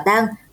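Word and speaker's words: tang (桑): Theo tiếng Hán, cây dâu tằm đọc là tang, đồng âm với tang thương, tang tóc nên thường gợi đến những điều không may.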